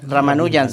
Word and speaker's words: Ramanujan: Ramanujan. He comprobado que sí es su pronunciación, por lo menos el apellido, Ramanujan. Sí. No Ramanujan como se escribe, pero bueno.